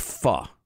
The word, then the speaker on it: pho: So now, a lot of West Coasters are already familiar with the fact that the delicious Vietnamese soup, spelled pho, is pronounced pho, and the number of Vietnamese places around the country named pho q, the letter q, too many to count.